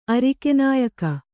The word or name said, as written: ಅರಿಕೆನಾಯಕ